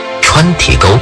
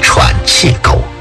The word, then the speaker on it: 川体沟: 川体沟发音不准就叫成了喘气沟，这个地名的含义也令人费解。